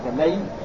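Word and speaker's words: لَيّ: يقول الشيخ رحمه الله تعالى قوله صلى الله عليه وسلم لي الواجد، اللي لي هكذا بهذا النطق، لي بفتح وتشديد الياء، المطل قلنا المطل يعني المطل يعني التأخير وعدم إعطاء الناس حقوقه.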